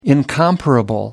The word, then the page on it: incomparable: British English